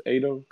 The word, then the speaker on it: Ado: I think it's Otto. I think it's Ado. Could be saying it wrong. It's A-D-O.